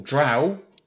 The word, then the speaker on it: Drow: Drow rhymes with cow. – And what else would it rhyme with? – I can tell you that because page 9 of A Grand Tour of the Realms, second edition Forgotten Realms box set, states Dark Elves, also called Drow, brackets, pronounced to rhyme with now or how.